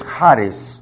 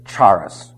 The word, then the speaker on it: charis: If you don't have it in your notes, it's charis. That's H-A-R-I-S. The H has a slight guttural sound to it. Don't ever pronounce it charis. Okay? That is not correct.